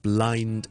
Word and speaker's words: blind: 好啦，而 blind 咧，exactly 一樣嘅 blind。 Tasting, blind tasting, blind tasting。先读咗个 lined 先，lined, lined, lined 完整嘅 l m lined，轻轻加个 b 直面就是 blind tasting，那就不会读成 blind tasting 了。